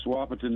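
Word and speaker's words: Wahpeton: Wapton, North Dakota. Am I saying it right? – Yes, Wapiton, North Dakota.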